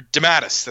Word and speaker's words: DeMatteis: I'm pretty sure it was Dematteis who was, or Dematis, that's how his name's pronounced.